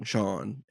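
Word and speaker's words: Sharn: They said that the way they spelled it or described it is it's S-H-A-R-N with a silent R, which is like S-H-A-A-N, Sean.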